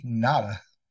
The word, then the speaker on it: Ngata: That's Haloti Nada.